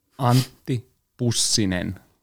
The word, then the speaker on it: Antti Pussinen: Antti Pussinen.